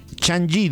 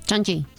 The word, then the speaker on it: Changi: Y el número uno, no sé cómo se pronuncia, está bien así, el Changi de Singapur. – Changi. – Sí. Está perfectamente pronunciado.